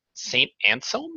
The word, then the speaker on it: Anselm: She is an associate professor at St. Anselm. Am I saying that correctly? St. Anselm College? – You're perfect.